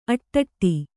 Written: ಆಟಿಡು

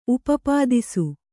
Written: ಉಪಪಾದಿಸು

